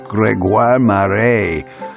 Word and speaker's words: Grégoire Maret: Oh, and for you aficionados of French jazz, his name is Grégoire Maret. Yes, jazz artist Grégoire Maret. I'm going to pronounce it for you once more. Grégoire Maret.